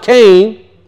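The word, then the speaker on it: Cain (כן): The root, parent root of the word Cain, and that's how it's pronounced, Cain.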